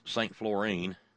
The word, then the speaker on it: St. Florian: called St. Florian, F-L-O-R-I-A-N, named after some saint, probably this patron saint of shoelaces or whatever, I don't know. And it, of course, is pronounced St. Florine, in case you were wondering. But out a little ways from St. Florine, in between St. Florine and Petersville, P-E-T-E-R-S-V-I-L-L-E, Petersville, there's an old renovated barn.